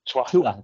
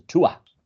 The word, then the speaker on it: Tuatha: Tuatha. Yeah, the Irish don't pronounce the TH sound because it doesn't exist in the Irish language. So Tuatha.